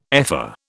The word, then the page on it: ephor: British English